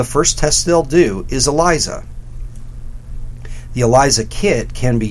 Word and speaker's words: ELISA: And ELISA is spelled E-L-I-S-A.